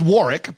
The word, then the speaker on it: Warwick: Pronounced Warwick, but yeah, Warwick. It does look like it's Warwick.